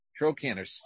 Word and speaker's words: trochanter: Trochanter. T-R-O-C-H-A-N-T-E-R.